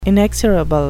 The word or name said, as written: inexorable